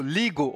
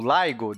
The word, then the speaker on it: LIGO: Meu querido Felipe, você conhece o Ligo ou Ligo? Depende da sua pronúncia, se é inglês ou português.